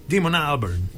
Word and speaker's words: Damon Albarn: Ah, Damon Albarn, in realtà si direbbe Damon Albarn.